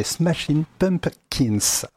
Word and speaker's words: Smashing Pumpkins: On commencera par euh, les smashing pumping, punk alors, alors, les smashing pumpkins. Voilà, pour le prononcer correctement.